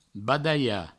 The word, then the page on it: badalhar: badaia; Provençal